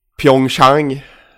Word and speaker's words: Pyeongchang: Eh, killen spelade ändå OS i eh, Pyeongchang. – Mm. – Jag reserverar mig för uttalet. – Helt men... korrekt.